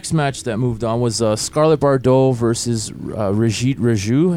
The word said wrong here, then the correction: Rohit Raju: Yeah, Rohit Raju. – Rohit Raju.